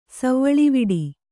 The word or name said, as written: ಸವ್ವಳಿವಿಡಿ